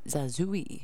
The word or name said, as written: zazuai